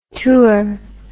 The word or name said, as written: tur